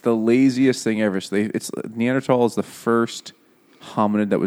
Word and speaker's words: Neanderthal: Neanderthal is the OG pronunciation. – No, but it sounds so stupid. – It's because it's a German word.